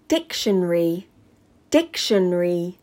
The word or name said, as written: Dictionary